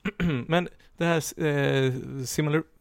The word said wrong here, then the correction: Silmarillion: vad heter, vad heter den? Similar, jag kan inte uttala det. – Silmarillion. Ja.